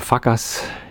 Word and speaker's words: FCUKERS: Also sie schreiben sich F-C-U-K-E-R-S. Ja, und natürlich ist der Bandname Fuckers.